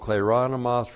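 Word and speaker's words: Kleronomos (κληρονόμος): So we have the particle men with the conjunction de, plus the nominative plural of kleronomos repeated with the genitive singular of theos,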